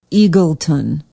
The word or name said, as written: eagleton